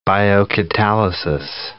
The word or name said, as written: biocatalysis